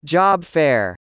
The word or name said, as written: Job fair